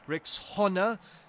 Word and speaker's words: Rexona: A friend called it Rexona, but guys, it's Rexona, like Rexona, Rexona.